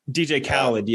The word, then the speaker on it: Khaled: it's uh, it's pronounced Cod, right? Yeah. DJ Khaled, yeah.